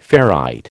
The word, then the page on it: ferrite: American English